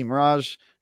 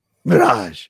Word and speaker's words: Mirage: Yeah, I've been playing AC Mirage. Cyberpunk. – You just said it wrong. – Mirage.